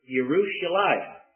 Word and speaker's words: Yerushalayim: The Jews of old would have pronounced Jerusalem as Yerushalayim. The J would be pronounced as a Y.